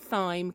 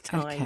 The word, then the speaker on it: thyme: I cooked a squash and, okay, apparently I say this wrong. I said a squash and thyme coconut curry, but apparently it's thyme.